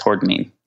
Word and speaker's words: hordenine: Yes, hordenine.